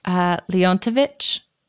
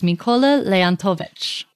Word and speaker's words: Leontovich: Leontovich.